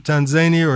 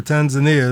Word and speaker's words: Tanzania: So if you pronounce it Tanzania or Tanzania, as I usually do, let's pray there for Sister Esther and for the soul of Sister Euphrasia.